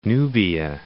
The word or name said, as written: Nubia